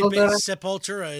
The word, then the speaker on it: Sepultura: That's actually Sepultura is basically how they pronounce it here. So yes.